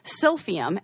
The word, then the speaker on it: Silphium: And it was us cultivating and using a plant called, now I'm going to have to try to pronounce it, Silphium, S-I-L-P-H-I-U-M, is a like a species of fennel, and it grows in the coasts of Greece.